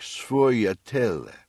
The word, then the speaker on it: sfogliatelle: I think the correct pronunciation is something like sfogliatelle. Sfogliatelle is a Sicilian dialect pronunciation. It's spelled S-F-O-G-L-I-A-T-E-L-L-E.